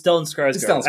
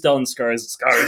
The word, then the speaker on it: Stellan Skarsgård: It's Stellan Skarsgård. I don't think you said Stellan Skarsgård. No. It is Stellan Skarsgård. – Could you say that one more time? – Stellan Skarsgård.